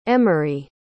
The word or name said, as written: emeri